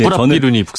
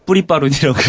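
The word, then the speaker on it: Prapiroon: 발음이 어려운 데 네, 뿌라삐룬이 북상한다. 저 뉴스에서 뿌리빠룬이라고 그랬거든요잘 어려워가지고 네, 큰일 난 적이 있어요.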